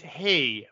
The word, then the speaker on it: hej: In high it's hey, but it's spelled H-E-J, though, I think. – Everything's got a J there, I think. So yeah, right. But it's just hey.